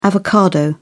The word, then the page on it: avocado: British English